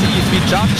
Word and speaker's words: Izvijač: Ich bin sehr Izvijač gesagt, wie belehrt worden, das heisst Izvijač.